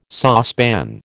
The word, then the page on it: saucepan: American English